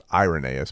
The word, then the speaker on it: Irenaeus: I mean, it's pronounced Irenaeus, but you would read it and say Irenaeus.